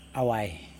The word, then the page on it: awaj: Yokoim